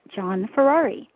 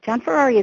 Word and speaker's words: Gianferrari: Take the first four letters, G-I-A-N, and pronounce them like the first name, John. The last part of my name is pronounced like the race car, Ferrari. Put them together and you get John Ferrari.